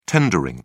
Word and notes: tendering: British English

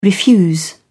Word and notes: refuse: British English

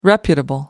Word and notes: reputable: American English

